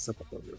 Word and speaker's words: sepulchre: I think it's sepulchre? the first syllable.